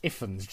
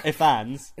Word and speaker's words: Ifans: It's pronounced Ifans. – And not Ifans.